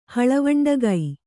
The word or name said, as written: ಹಳವಂಡಗೈ